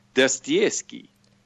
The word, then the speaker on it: Dostoevsky: We try to say it with a slight Russian accent because we think it sounds even better. So we say Dostoevsky.